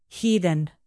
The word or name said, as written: heathen